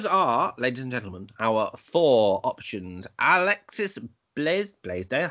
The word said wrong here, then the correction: Bledel: I'd say Bledel or Bleedle. – Bledel. I was putting an extra S in there.